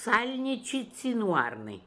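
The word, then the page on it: Salnichizinooarnian: Russian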